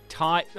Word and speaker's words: Tadhg: T-Y-H-D-E. – Nah. – Dang. – How do we spell it? – T-A-D-H-G. – H-G? – Yeah. – Is that Tide? – Felt weird. – Tide. Oh, yeah. Like Tide. Like Tide Kennelly, the footballer, not Tide, something that happens in the ocean.